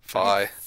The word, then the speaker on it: Fi: Like Andy saying fee, which is wrong. – Ugh, gosh. Yeah, that's correct. – Fi. – So wrong.